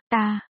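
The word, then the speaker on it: tā: Là nữ, ba, vì trong tiếng Trung đại từ chỉ người ta, chỉ nam, và ta, chỉ nữ, đều phát âm là ta,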